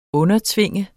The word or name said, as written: undertvinge